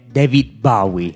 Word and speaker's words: David Bowie: siamo irradiati e correggendo la pronuncia è David Bowie.